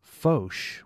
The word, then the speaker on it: Foch: Evan says, it's pronounced Foch with a long O, and I know this from four generations of family pronouncing it this way.